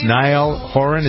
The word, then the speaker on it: Niall Horan: Second one comes from, I don't know this artist, Niall Horan.